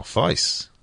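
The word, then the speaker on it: Faes: Oh, Face.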